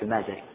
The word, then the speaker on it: المازري: المالكية عندهم كتاب هو كتاب المذهب لا قبله ولا بعده، ولذلك كل القواعد المستخرجة يرجعون لهذا الكتاب، وهو كتاب شرح التلقين للمازري، بعضهم ينطقه المازري وبعضهم ينطقه المازري، والأشهر بالفتح المازري.